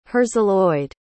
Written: herzeloyde

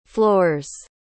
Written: floors

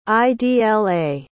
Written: idla

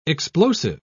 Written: explosive

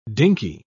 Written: dinky

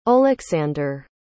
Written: Oleksander

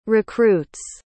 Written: recruits

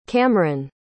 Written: camren

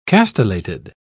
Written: castellated

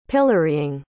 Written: pillorying

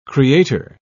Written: creator

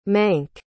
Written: mank

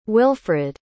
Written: wilfrid

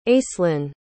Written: acelynn

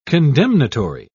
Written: condemnatory

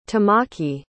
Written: tamaki

